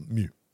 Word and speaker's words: Meux: spelled M E U X yep. and Co's, but uh, we I think it's called Mu. Mu, yeah, Mew. yeah. When, Mew when the Co, Google yeah.